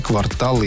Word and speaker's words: квартал: Она также отметила, что исключительно на последний слог ударение падает в словах «жалюзи», «квартал» и «диспансер».